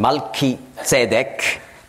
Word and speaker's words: Melchisedek: Hier übersetzt also Paulus, der ja der Autor ist des Hebräerbriefes, wie wir schon gesehen haben in früheren Bibelstudientagen, er übersetzt Melchisedek. Hebräisch ausgesprochen Malchisedek, das heißt König der Gerechtigkeit.